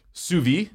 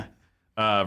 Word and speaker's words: sous vide: Sous vide. Did I say that right? – Yeah. – Sous vide versus the air popper.